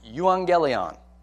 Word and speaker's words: euangelion: So, gospel in Greek, if we just want to talk about the term, is pronounced euangelion. And there's a prefix and then like a root word. So the prefix, eu, and then the root word, angelion. Did you hear that? Euangelion.